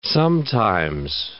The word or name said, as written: sometimes